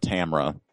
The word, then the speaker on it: Tamra: I mean, that's what Tamara Bonvillain said, was that, you know, it's a French name. – Mm-hmm. – Not Tamara. Tamra.